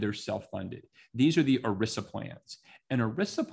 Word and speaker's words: ERISA: The other issue has to do with something called ERISA, which rhymes with Melissa because I kept mispronouncing it.